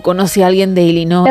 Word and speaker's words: Illinois: Illinois. – Vamos a poner la parte corta. – Illinois.